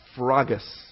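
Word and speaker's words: Sphragis (σφραγίς): Kind of hard to pronounce. S-P-H-R-A-G-I-S. Sphragis. As English speakers, we don't like having all those consonants up front in that word. S-P-H-R. Sphr. We don't have many words that start with a sphr sound.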